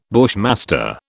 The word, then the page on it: bushmaster: British English